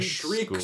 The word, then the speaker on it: Shreek: Yeah, and they go to this school, and it's spelled S-H-R-E-E-K, the Shriek School, for a great play on words. But my first inclination was to say, if you remove an E, it becomes the Shrek School.